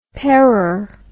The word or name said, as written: parer